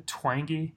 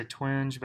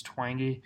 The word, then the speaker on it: Twenge: entitled "Have Smartphones Destroyed a Generation" by Jean Twenge, I believe is how you pronounce her name. Some people say Twenge, but I think it's Twenge.